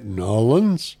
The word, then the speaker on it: New Orleans: in New Orleans, or as the locals say, Nolans.